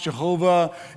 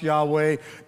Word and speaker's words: Yahweh: And so the YHWH or the YHVH, a lot of people used to think it was pronounced Jehovah. Now we're thinking it's probably more correctly pronounced Yahweh.